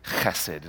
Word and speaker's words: chesed: And so, what we see in English is hesed, is pronounced chesed.